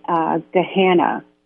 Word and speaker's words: Gahanna: was Gahanna. I hope I said that correctly. – You did, and, yes.